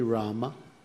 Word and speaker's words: Rama: It should be Rama. So short A, we should say Rama. Sometimes the A is not vocalized very much, but if you listen closely to Srila Prabhupada chanting, it is Rama.